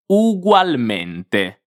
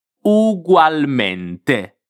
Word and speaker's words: ugualmente: Pronunciamo insieme la parola ugualmente. Attenzione alla T finale, la voglio sentire bene, come una T, T. Ugualmente. Ugualmente.